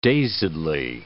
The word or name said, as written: dazedly